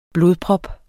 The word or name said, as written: blodprop